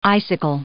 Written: icicle

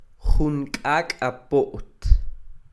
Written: Jun k'ak'a po't

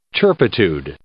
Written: turpitude

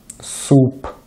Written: суп

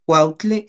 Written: kuautli

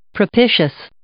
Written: propitious

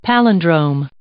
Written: palindrome

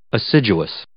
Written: assiduous

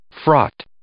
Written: fraught

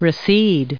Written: recede

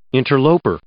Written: interloper

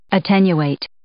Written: attenuate

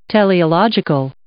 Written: teleological

